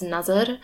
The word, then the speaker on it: Nazar: But I know that people have called me out on that before and been like, it's not Najr, it's Nazar.